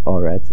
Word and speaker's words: Eretz: So Eretz is at the end of a pasuk or at a mafsik, is changed to a comet. Instead of Eretz, it becomes Oretz, and the stress remains on the Aleph.